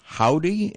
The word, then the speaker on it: Hovvdy: Here's music from the buzzy group Howdy, and it's spelled H-O-V-V-D-Y with the two V's right next to each other, resembling a W.